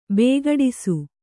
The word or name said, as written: ಬಿಕ್ಕಡಿಸು